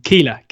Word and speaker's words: Keillor: Spelling it wrong and saying it wrong. So it comes up on the video printer as Kellyer Dunn. And Jeff, you know, as he would, says Kellyer Dunn. Keeler, lads, K-E-I-L-L-O-R.